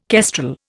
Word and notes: kestrel: Indian

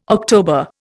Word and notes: October: Indian

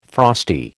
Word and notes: frosty: American English

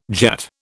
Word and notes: Jat: British English

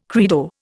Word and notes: credo: Indian

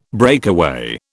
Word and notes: breakaway: British English

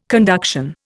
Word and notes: conduction: Indian

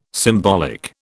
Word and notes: symbolic: British English

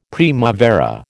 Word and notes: primavera: American English

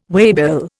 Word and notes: waybill: Indian

